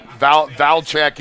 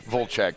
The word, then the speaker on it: Vlchek: But, yeah, like, Vlcheks is, like, the one is how you would hear it pronounced the most. My grandmother would have said Vlcheks.